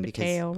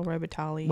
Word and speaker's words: Robitaille: Um, so the legend has it, this legend um, had it that many years ago, the Candyman was a slave named Daniel Robitali mm. which I'm sure is not how you say his name. Robitaille, Robitaille.